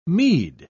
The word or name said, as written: meed